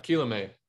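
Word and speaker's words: Kilome: Hope I'm saying that right. I think we saw him a little bit in 2020. He's actually on the Mets prospect list still. – Yeah, Quilome. – Quilome. Apologies.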